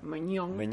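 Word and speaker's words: Maignan: Y vamos con el siguiente, que se confirmó también hace poquito, que es Magnon, No sé si pronuncia así. Meñón. – Meñón, el portero del Lille, el Lille, campeón de la Liga Francesa.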